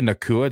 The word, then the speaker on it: Nacua: Puka Nacua.